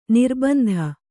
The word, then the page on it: ನಿರ್ಬಂಧ: nirbandha